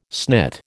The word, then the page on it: snit: American English